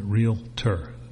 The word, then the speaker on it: realtor: They understand what a realtor, and I'm even pronouncing it, realtor has two syllables in it, realtor. There's no realtor.